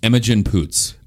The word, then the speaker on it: Imogen Poots: Imogen Poots.